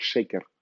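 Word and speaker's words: шекер (שקר): Вот интересно, что еврейское слово «ложь» читается и произносится, произносится как «шекер».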